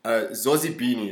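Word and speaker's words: Zozibini: Okay, anyway, first, before we get into that, because I wanted to ask you, shout out to Zozi Beanie.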